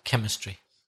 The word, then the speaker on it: chemistry: I think the first thing to say, perhaps is the pronunciation of chemistry, because sometimes I hear my students say chemistry, but you don't pronounce the CH, do you? – It's the K sound, like K, like in Christmas, CH, or Christina. Yeah, chemistry.